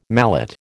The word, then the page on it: mallet: American English